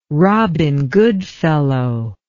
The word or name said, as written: robin goodfellow